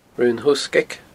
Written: Raon Thoghsgaig

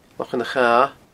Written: Lochan a' Chatha

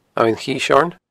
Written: Abhainn Chiseoirn

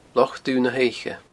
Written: Loch Dubh na h-Oidhche

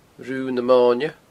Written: Rubha na Moine

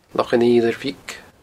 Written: Loch an Fhidhleir Bhig

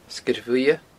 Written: Sgeir Bhuidhe

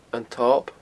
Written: antob

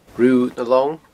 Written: Rubha nan Long

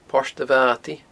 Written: Port a Bhata

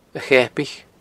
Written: Acheapaich

